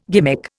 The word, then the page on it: gimmick: Indian